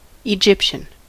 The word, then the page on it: Egyptian: /ɪˈdʒɪp.ʃən/